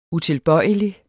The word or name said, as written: utilbøjelig